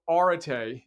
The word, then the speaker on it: arete: So arete is how you pronounce the book's title.